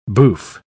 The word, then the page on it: bouffe: British English